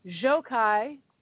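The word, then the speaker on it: Gjokaj: Enver Jokaj, it's G-J-O-K-A-J, and I had to have somebody write down how to pronounce it, who played Pete, who rescues the governor and Megan Tara's niece in season 4, February 12th, Orange County, California.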